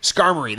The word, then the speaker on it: Skarmory: Like, I knew enough about Gen 2, but there's a few Pokemon I never discovered before, like Sky Armor. It's the bird that's made of steel. Armor. Skarmory, that's it.